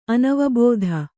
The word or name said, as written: ಅನವಬೋಧ